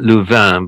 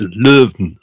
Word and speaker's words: Leuven: I would call it Louvain, which is roughly the French pronunciation.